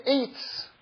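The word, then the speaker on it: Eitz (עץ): There's a very interesting teaching that I heard in the name of the Ari, which is that the word Eitz, which is the Hebrew word for tree, Eitz is spelled Ayin Sadi. And Ayin is one of two Hebrew letters that are silent.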